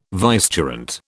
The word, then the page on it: vicegerent: British English